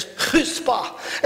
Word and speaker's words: chutzpah: It's spelled chutzpah, but it's chutzpah.